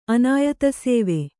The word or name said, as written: ಅನಾಯತಸೇವೆ